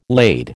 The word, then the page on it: lade: American English